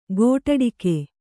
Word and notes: ಗೋಟಡಕೆ: kannada

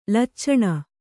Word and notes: ಲಚ್ಚಣೆ: Kannada

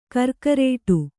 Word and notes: ಗಿರಿಕಾರೂಢ: girikārūḍha

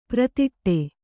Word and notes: ಪರದಾಡು: Kannada

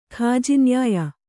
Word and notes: ಕಾಜಿನ್ಯಾಯ: kājinyāya

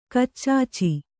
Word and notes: ಗಚ್ಛ: gaccha

